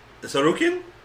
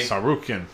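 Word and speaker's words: Tsarukyan: The next match was Aubin Mercier versus, you want to take a crack at pronouncing that name? – Sarukin? – Sounds right to me. – Sarukin. – Yeah, I think the T is silent.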